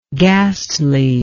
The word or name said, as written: ghastliness